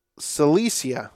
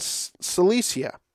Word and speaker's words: Cilicia: Later, I went to Syria and Sicily. Cilicia. Sorry, I have a hard time pronouncing that one. Later, I went to Syria and Cilicia.